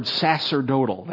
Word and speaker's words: sacerdotal: It's the word sacerdotal. That's pronounced like an S, the C there. Sacerdotal.